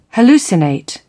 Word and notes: hallucinate: British English

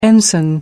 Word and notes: ensign: American English

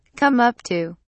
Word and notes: come up to: British English

